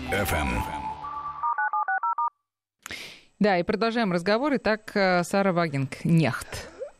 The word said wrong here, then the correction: Вагенкнехт: Сара Вагенкнехт. Вагенкнехт. Я вот тоже начинаю правильно, наконец, произносить немецкие фамилии.